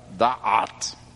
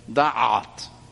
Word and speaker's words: Da'at: Y tenemos la tercera palabra, Da'at. Y como tiene una Ain, hay que apretar un poquito aquí en la garganta para pronunciarla, Da'at.